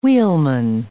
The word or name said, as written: wheelman